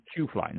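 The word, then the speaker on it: Chuflay: You said C-H-O-O was how it's pronounced, which is chew. – Q. You're right. Shoefly, not shoe. Q.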